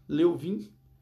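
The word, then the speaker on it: Lewin: Os estudiosos como Levin, Leu, né, Leuvin melhor dizendo, né?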